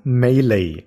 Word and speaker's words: Melee: Und alle sagen Melee, so wie ein orientalischer Drink. Melee.